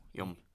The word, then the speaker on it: yom: It's Y-O-M. How would you pronounce that? – Y-O-M? – Yeah. – Yum. – Yum. Yum.